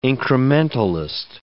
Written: incrementalist

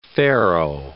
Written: faro

